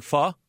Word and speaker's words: Pho: It's spelled P-H-O. Did I get it right in saying Pho? – That is correct. You got it down. Yes. – Think what is it? Yeah. – F-U-H.